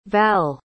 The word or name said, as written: val